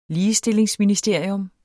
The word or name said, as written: ligestillingsministerium